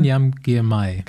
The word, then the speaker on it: Binyam Girmay: Binyam Girmay.